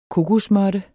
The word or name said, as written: kokosmåtte